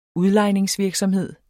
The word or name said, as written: udlejningsvirksomhed